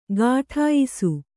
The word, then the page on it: ಗಾಠಾಯಿಸು: gāṭhāyisu